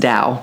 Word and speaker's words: Tao: It's called I think it's pronounced Tao, but it's spelled T-A-O, The Tao of Daily Life.